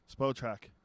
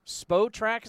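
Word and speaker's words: Spotrac: Spotrack. – Spotrack is how you say it, Spotrack.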